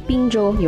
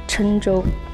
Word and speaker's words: 郴州: 那我本人是来自湖南，呃，湖南这个郴州，不知道有多少人听说过哈？那个郴字呢，可能很多人还不会念，怎么写呢？一个双木林，一个右耳旁，很多朋友可能会念成滨州，有没有？有的话回应我一下。对，它应该是念郴，郴州。对，应该是念郴州。